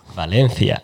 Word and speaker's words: Valencia: Do the Spanish pronunciation. Valencia. – Valencia.